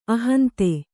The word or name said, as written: ಅಹಂತೆ